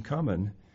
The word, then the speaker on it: cumin: For you pay tithe of mint, anise, and cumin, and have neglected the weightier matters of the law." Did I pronounce that right? It's cumin, isn't it? Or is it cumin? Cumin, yes.